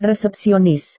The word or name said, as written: resepsionis